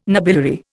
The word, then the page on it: nobiliary: Indian